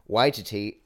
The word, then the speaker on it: Waititi: Wait, did I say Waititi. – That right? – Waititi. Come on, Doug. We literally pulled up a YouTube video of him saying it to make sure that we did not butcher it in the podcast.